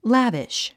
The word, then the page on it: lavish: lǽviʃ; ラビシュ